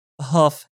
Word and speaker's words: Huff: Let's go for a question from Rob Huff. You would pronounce it like H-U-F-F.